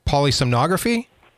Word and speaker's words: polysomnography: Okay, so let's talk about the sleep study, which I believe is technically termed polysomnography.